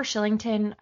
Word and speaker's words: Kylington: hmm I, I think they're Oliver Kylington.